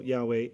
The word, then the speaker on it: Yahweh: And for those who are ultra conscious about mispronouncing it, even the word, even the pronunciation Yahweh is not the correct pronunciation. We actually swap out the vowel points. The consonants are correct, yod, hey, vav, hey, right? But the consonants, the vowels are swapped out.